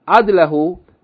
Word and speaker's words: عدل: بفت فسكون means here that the word عدل, يعني عدل, means that the first letter has fatha and then فسكون, the second letter has سكون. So it's pronounced عدلٌ, عدلٌ. But here it has fatha because it's the مفعول به, so it became عدلهُ.